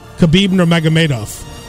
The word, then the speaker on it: Nurmagomedov: Khabib Nurmagomedov.